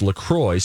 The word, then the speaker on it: LaCroix: Officially, it's pronounced LaCroix so that it rhymes with enjoy, not La Croix or La Croix like the French would attempt to say a word like that.